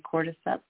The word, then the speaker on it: cordyceps: Cordyceps? – Cordyceps.